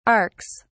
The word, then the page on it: arks: British English